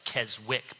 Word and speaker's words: Keswick: Looks like Keswick, but the W is silent.